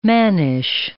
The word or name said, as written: mannish